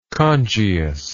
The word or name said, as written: congius